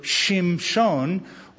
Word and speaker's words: Shimshon: Now I didn't say it right. It's it's Samson in English, but in Hebrew, Shimshon, which is a word that means of the sun or sunny boy.